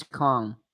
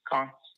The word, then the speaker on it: Kong: First name is Kong. – Can you spell it for me, Kong? – Kong, like King Kong, Donkey Kong, K-O-N-G. – King Kong, K-O-N-G.